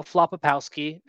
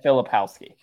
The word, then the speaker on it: Filipowski: Is that how you pronounce his name? I don't want to go this whole time without. I mean, should I just say I think it's Kyle Filipowski.